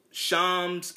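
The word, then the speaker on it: Shams Charania: I mean, Shams. Because that's how you pronounce it. Shams. It's not, you know, the NBA insider, Shams Sharana. They call, I don't know why people pronounce it Shams. It's Shams. Shams Sharana.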